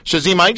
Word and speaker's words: Shazim: Now, Shazim, I need some help with pronouncing your name.